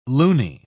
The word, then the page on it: looney: British English